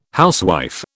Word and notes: housewife: British English

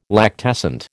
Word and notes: lactescent: American English

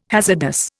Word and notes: hazardous: Indian

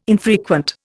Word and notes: infrequent: Indian

